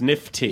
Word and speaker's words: NIFT: I think it's pronounced nifty.